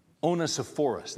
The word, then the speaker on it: Onesiphorus: I still can't say that name. I'm going to get it right. Onesiphorus, that's how you say it.